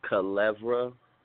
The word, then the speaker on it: Kilevrah: Yeah, it's pronounced Kilevra. K-I-L-E-V-R-A-H, Kilevra.